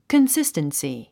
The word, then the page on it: consistency: kənsístənsi; コンスィステンスィ